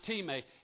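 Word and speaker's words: time (τιμή): Now, this is not the English word time. It's the Greek word time. And it's pronounced time and transliterated into English.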